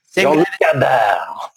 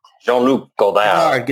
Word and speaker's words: Jean-Luc Godard: Jean-Luc Godard. – What's his name? – Jean-Luc Godard.